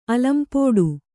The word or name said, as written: ಅಲಂಪೋಡು